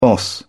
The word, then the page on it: boss: British English